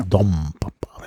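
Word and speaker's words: Don Papa: Dom Papa, wird das natürlich ausgesprochen. Dom muss ich mal richtig vormachen, weil das ist immer meinen Studenten beibringen will, dass es assimiliert wird im Spanischen.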